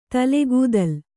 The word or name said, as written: ತಲೆಗೂದಲ್